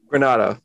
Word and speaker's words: Granato: They seem to just be playing really hard under Granato. Or is it Donato? Why do I do this every episode? – You said it right. – I said both. Is it Granato? – Granato.